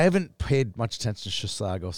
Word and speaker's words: Chisago: Two Rivers plays Chisago. Notice how I said that right? – Yes, you did.